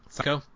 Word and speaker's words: Seiko: And obviously our leading lady here, Seiko, am I pronouncing that right? – Seiko. – Seems even more fitting when you say it correctly. – It's a homonym with obviously English psycho, right? – And exactly.